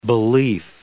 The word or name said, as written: belief